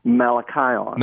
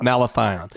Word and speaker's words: malathion: So we need to get rid of the insect problem for sure, and you can do that with malathion. – Malathion. – Malathion. Let me spell that for you. Mala, M-A-L-A, thion, T-H-I-O-N. – T-H-I-O-N.